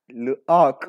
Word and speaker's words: L'Arc: No, it's like L apostrophe arc. – L'Arc.